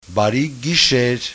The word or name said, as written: barigisher